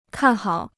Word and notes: 看好: kàn hǎo

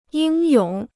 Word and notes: 英勇: yīng yǒng

